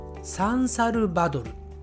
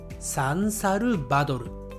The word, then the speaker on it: サンサルバドル: サンサルバドルサンサルバドルはい、